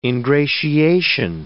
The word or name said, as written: ingratiation